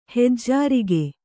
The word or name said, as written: ಹೆಜ್ಜಾರಿಗೆ